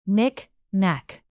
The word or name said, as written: knick-knack